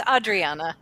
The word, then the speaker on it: Adriana: Close. It's Adriana.